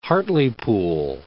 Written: Hartlepool

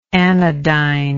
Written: anodynic

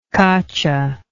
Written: caccia

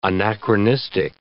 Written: anachronistic

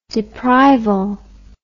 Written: deprival

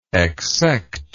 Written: exsect

